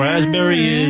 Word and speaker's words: raspberry: Raspberry is the silent S. – P. – Or a P. Sorry. – Silent P, right?